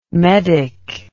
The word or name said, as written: medick